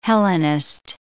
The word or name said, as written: Hellenist